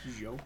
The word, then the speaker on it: DJO: It's either DJO, DJO, or Joe. – Joe. I kind of like Joe.